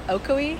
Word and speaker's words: Ocoee: Ocoee, Florida, right.